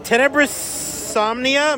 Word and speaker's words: Tenebrosomnia: We're still behind the New Blood booth with the very spooky Tenebrosomnia.